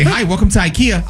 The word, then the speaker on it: IKEA: I've been saying it wrong? – Yeah. – This says it's IKEA. IKEA.